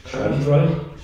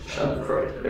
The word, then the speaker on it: Schadenfreude: How do you say that word? – Schadenfreude? – Schadenfreude. There we go.